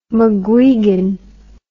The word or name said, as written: mcguigan